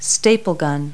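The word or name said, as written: staple gun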